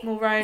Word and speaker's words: Mulroney: Mulroney.